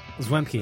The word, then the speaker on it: Zwemke: Zwemke.